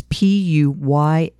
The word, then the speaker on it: Puyallup: Misty Donna Copsey was 14 years old when she went missing from, I hope I say this correctly, Puyallup, Washington on September 17th, 1992. That's Puyallup. Mm-hmm. Puyallup? – It sounds good to me.